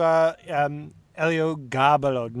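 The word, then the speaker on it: Eliogabalo: Let's get into Elio Gabalo. Now am I saying it right? – Yes, yes. – Oh, there we go. The accent is on the ga.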